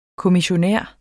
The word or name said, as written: kommissionær